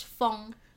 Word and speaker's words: 封: Another very similar pronunciation is feng. – Feng, okay. – F-E-N-G. – But not your crazy feng. Not feng's feng. Same pronunciation. – Okay. – 但是不同的 characters. – Okay, how? This is first tone, right? – 对.